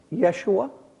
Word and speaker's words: Yeshua: It would be pronounced Yeshua, which means Savior. So the one standing there is a symbol of the Savior. And in the New Testament, Yeshua would sound like this in Greek. Jesus.